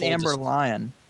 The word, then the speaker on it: Amber Lyon: Like, everybody who's doing podcasts are really furthering the dialogue and the truth and I mean, do you guys know about Amber Leone? She was fired from CNN because she was repeatedly I think it's Amber Lyon.